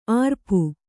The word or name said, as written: ಅರಪು